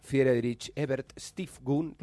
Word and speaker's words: Friedrich Ebert Stiftung: Esto es fue publicado por el Centro de Estudios para la Ciudad, junto a una fundación alemana, Friedrich Ebert Stiftung, lo dije mal probablemente. – No, sonó muy bien igual.